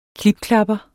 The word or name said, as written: klipklapper